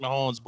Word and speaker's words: Mahomes: Patrick Mahomes, and thank you for correcting yourself. I certainly appreciate it. – You're welcome. – That's how you say his name, Hall of Famer Patrick Mahomes.